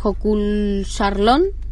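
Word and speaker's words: Jökulsárlón: Y en ese lugar, en Hokulsarlón.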